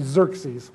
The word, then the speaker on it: Xerxes: And we know from history that the fourth king after 536 BC was a fellow by the name of Xerxes. That's how you pronounce that. Okay? Makes you want to slobber when you see. But that's actually Xerxes.